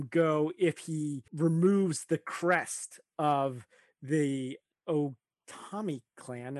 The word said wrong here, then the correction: Ogami: Ogami clan, sorry.